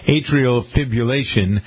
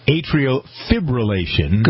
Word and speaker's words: atrial fibrillation: on atrial fibrillation. You know, I don't think I ever pronounced this correctly. I'm a big one on R's, Ken. It's actually atrial fibrillation.